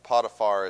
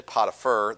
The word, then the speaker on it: Potiphar: We're going to get into Joseph and Potiphar's house. I will address the elephant in the room. I was taught that Potiphar is the correct way to say it, so I've said it ever since I was taught it.